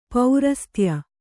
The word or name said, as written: ಪರಸತ್ಯ